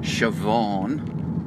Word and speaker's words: Siobhan: as in Siobhan.